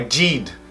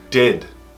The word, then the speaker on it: did: Preste atenção na pronúncia do did. Não é did, did. E-e, did.